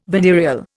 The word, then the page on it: venereal: Indian